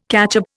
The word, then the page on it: catchup: Indian